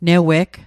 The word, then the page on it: neweg: Mi'gmaq